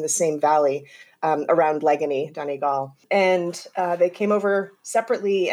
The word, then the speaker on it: Donegal: Leganí, you got the first one right, and that's impressive. And Donegal. So well done.